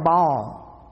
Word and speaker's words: Baal: Her father's name was Ethbaal, named after the god Baal or Baal. It can be pronounced either way.